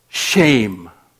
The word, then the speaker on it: shem: This Hebrew word is literally pronounced shame. It is spelled S-H-E-M, and its literal translation is name.